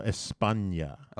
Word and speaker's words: España: I just want you to pronounce it correctly. – España? – No, España. – Okay. – You got to pronounce it. You got to give it the correct Spanish accent. If you're going to say Spanish words, you got to say it like you're supposed to say it.